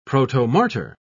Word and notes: protomartyr: British English

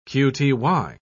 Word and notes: qty: British English

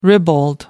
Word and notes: ribald: American English

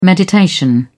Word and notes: meditation: British English

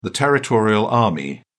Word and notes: territorial army: British English

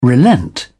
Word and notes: relent: British English

